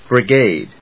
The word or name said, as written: brigade